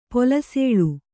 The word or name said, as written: ಪಲ್ಸುಲಿ